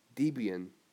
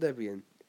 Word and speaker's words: Debian: Debian, or Debian, however you wish to pronounce it. I'll pronounce it Debian.